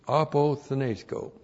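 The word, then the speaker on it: apothenesco (ἀποθνῄσκω): And then we have apothenesco. A-P-O-T-H. N, long E, S, K, long O, apothenesco.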